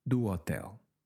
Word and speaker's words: hotel: Do hotel.